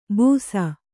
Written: ಬುಸ

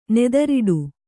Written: ನೆದರೊಡೆ

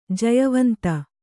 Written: ಜಯವಂತ

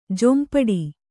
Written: ಜೊಂಪಡಿ

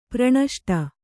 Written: ಪ್ರಣಷ್ಟ

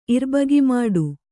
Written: ಇರ್ಬಗಿಮಾಡು

